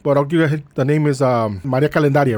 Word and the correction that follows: Candelaria: Candelaria. – Candelaria. Yeah, I said it wrong a couple of times. I kept saying calendaria like calendar.